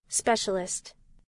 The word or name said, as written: specialist